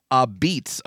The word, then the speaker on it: apizza: Now, it's spelled A-P-I-Z-Z-A, but pronounced Abeats, according to this Wikipedia. That's the local dialect.